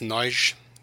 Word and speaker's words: Nagy: It's pronounced Nagy, just saying.